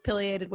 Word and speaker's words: pileated: We know Wild Rock is a place with trails and a place with pileated woodpeckers. Did I say it right? – Yes. – Yes, with pileated woodpeckers.